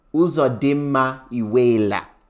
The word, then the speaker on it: Uzodinma Iweala: The film is based on a 2005 novel of the same name by, for the Nigerians there, Uzodinma Iweala. That's how it's pronounced.